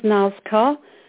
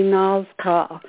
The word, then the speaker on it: Nazkaa: Okay, her name is Nazcar. It's spelled N-A-Z-K-A-A. That's my understanding of how to spell it and how to pronounce it. It's like a long A, Nazcar.